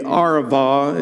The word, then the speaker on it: Arava: Verse 8, he said to me, this water flows toward the eastern region and goes down to the Arava, is a B, but it's pronounced V, the Arava, where it enters the Dead Sea.